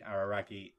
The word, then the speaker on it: Araragi: The thing is, the scene I'm referring to, by the way, to clarify, in Monogatari is, of course, the one between Araragi and Senjigahara.